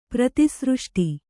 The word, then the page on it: ಪ್ರತಿಸೃಷ್ಟಿ: prati sřṣṭi; kannada